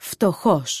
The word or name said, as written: φτωχός